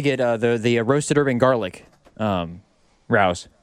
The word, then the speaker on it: Rao's: May I suggest Rao's, I believe is how it's pronounced. R-A-O.